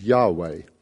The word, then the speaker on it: Yahweh: In the English translations, it says Lord in capital letters, L-O-R-D, but that actually stands for the divine name, which as far as we can tell would have been pronounced Yahweh.